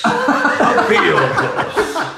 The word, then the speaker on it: Ophiuchus: Ophiuchus. – Ophiuchus. – That was Yoda describing it. Ophiuchus. – Ophiuchus. It's like a sort of spell in Harry Potter. – For those who don't know, Ophiuchus.